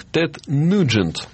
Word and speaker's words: Тед Нюджент: фамилия правильно произносится как Тед Нюджент.